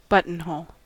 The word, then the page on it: buttonhole: American English